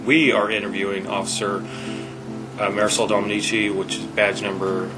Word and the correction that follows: Domenici: It's actually pronounced Domenici.